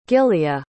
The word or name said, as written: gilia